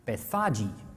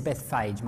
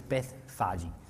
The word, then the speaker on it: Bethphage: Now, when they drew near to Jerusalem, to Bethphage, I recently found out that's pronounced Bethphage. I never knew that. Anyway, I've always said Bethphage my whole life. Apparently, it's Bethphage.